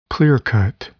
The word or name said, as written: clear-cut